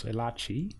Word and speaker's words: Elachi: So, I went along, and Susie did too, to a talk given by Dr. Charles Elachi. I think I'm pronouncing his name right. Who That's is right.